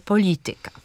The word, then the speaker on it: polityka: Drugą sytuacją, kiedy akcentujemy trzecią sylabę, są rzeczowniki łacińskie lub neołacińskie zakończone na ika i yka. Mówimy zatem botanika, etyka, galaktyka, pedagogika czy polityka.